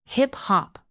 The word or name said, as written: hip-hop